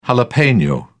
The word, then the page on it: jalapeno: British English